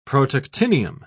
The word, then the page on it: protactinium: British English